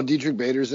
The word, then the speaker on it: Diedrich Bader: And, I don't know if I ever pronounced this guy's name right. Diedrich Bader. – That's it. You got it. – Oh, I did? Oh. – Diedrich Bader, yeah.